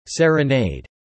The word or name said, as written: serenade